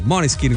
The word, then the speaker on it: Måneskin: Allora, a proposito di rock, è scoppiata la Maneskin, anzi Måneskin, come oramai abbiamo capito bisogna pronunciarli, la Måneskin mania.